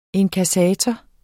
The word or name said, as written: INKASSATORER